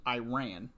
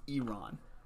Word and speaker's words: Iran: He was asked, first of all, he was corrected that it's not pronounced Iran, it's pronounced Iran.